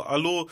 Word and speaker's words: Hallo: Allo Allo, ja, ich bin da, weißt du, so Allo, so bei uns äh, in der Kultur sagt man Allo, dieses H wird fast gar nicht ausgesprochen so und äh, das kommt halt von zu Hause, so dieses Allo, Allo.